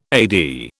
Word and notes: AD: British English

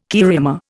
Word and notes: cariama: Indian

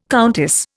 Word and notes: countess: Indian

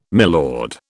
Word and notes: milord: British English

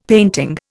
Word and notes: painting: Indian

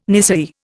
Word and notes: nisei: Indian

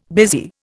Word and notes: busy: Indian

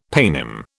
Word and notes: paynim: British English

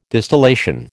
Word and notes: distillation: American English

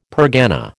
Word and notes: pergana: American English

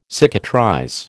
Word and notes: cicatrize: American English

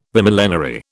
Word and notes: Bimillenary: British English

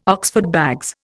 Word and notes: Oxford bags: Indian

